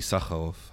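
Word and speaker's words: Issacharoff: which was developed by Lior Raz and Avi Izarov. No, is Akharov? How do you pronounce his last name? Avi Sakharov. Okay, thank you.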